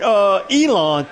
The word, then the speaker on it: Elon: The quarterback from Elon, if I'm pronouncing it right, E-L-O-N. – Yeah, Elon.